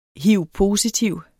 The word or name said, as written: hiv-positiv